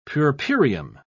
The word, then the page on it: puerperium: British English